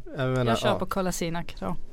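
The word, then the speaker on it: Kolašinac: Men han uttalar ju tydligen fel själv. Okay. Det är det som är problemet. – Men visst är det så, ja. – Eftersom han är väl född och uppvuxen i Tyskland. – Ja. – Så att han har liksom ett tyskt uttal. Så att alla bosnier som hör det, de tycker att vad är det där? Det där stämmer inte alls. Men han säger Kolasinac, fast det ska vara Kolasinac, tror jag, eller sånt där, egentligen.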